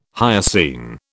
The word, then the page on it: hyoscine: British English